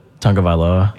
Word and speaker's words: Tagovailoa: Tagovailoa? – Yeah.